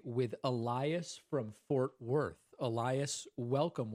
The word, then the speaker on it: Elias: So, for example, I don't want to correct you, but you said my name wrong. It's Elias, like the Spanish way.